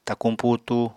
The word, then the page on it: takuñ putu: apatani